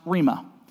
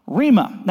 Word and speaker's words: rhema: The word that he uses in this specific passage is pronounced rhema. Rhema.